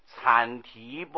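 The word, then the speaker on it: 羼提: 其次第三阐提波罗蜜经文呢，四字下面三个羊，三只羊那是念作阐字，好像这个生产的产，产业的产，同音呢、啊？阐提波罗蜜，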